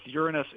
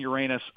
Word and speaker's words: Uranus: the pronunciation of that planet, both Uranus and Uranus are acceptable.